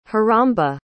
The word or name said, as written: heramba